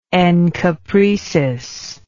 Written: encopretic

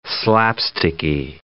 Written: slapsticky

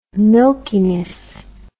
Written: milkiness

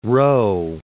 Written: Rowe

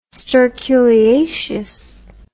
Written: sterculiaceous

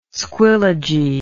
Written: squillagee

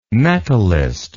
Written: metalist

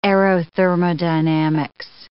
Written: aerothermodynamics

